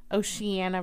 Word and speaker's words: Oceana: Naval Base in Ocean, Virginia. – Oceana, Virginia. – Well, that's not how I would spell Oceana. – I know, but that's how it's spelled.